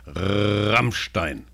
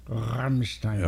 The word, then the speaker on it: Rammstein: Yeah, there you go, Yeah, the band is called This is how you pronounce the R correctly. Rammstein. – Rammstein.